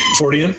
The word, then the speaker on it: Fortean: And congratulations on pronouncing it correctly. – Fortean? Mm-hmm. Oh, yeah, a lot of people don't.